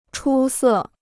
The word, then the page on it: 出色: chū sè